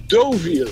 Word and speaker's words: Deauville: Dat is, ze eh, ik, ik erg me mateloos aan Duviel. Dat ding heet gewoon Doviel. Oh. Oh. spreek je het uit.